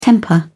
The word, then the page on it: temper: British English